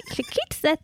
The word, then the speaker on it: Chikitsa: She's an Ayurvedic practitioner and body worker, yoga teacher, holistic wellness educator, trained in ancient Ayurvedic body work, including Marma Chistika. Hope I said that right. Chikitsa therapy and foundational Panchakarma therapies.